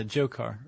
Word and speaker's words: Dzhokhar: And then the one, I guess, is that Dzhokhar? The D is silent, right? – Yeah, Dzhokhar or Dzhokhar.